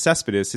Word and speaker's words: Céspedes: So the guy says his name is Yoenis Céspedes.